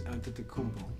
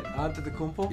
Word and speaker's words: Antetokounmpo: Antetokounmpo, right? – How do you say it? – Antetokounmpo? – Yeah. You say it Spanish. He's not Spanish. He's Greek.